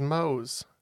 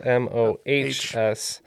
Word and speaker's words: Mohs: Yeah, it's uh, it is Mo's. Yeah, it's spelled M O H S, but it is the Mohs scale.